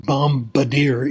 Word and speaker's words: bombardier: It turns out if there's a B at the end of a word and it comes after M, it's not pronounced. But there are exceptions, like bombing, it's not pronounced, but bombardier it is.